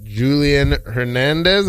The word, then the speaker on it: Julian Hernandez: All right, and today's special guest, we got Julian Hernandez.